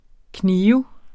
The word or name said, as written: knibe